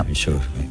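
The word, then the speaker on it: Verisure: Very pero sure. Very Sure, ay, que me lo dice, eso es. Bueno. – Very Sure.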